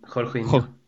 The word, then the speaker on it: Jorginho: Men efter att Lampard gör sitt byte och släpper in hur uttalas det ens? Jorginho eller Jorginho? – Jorginho.